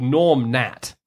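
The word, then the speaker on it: Gnorm Gnat: He came up with a comic strip called Norm Nat. Right, Norm is spelled with a G, so Gnorm, but like a Nat is spelled with a G, so he called it Norm Nat.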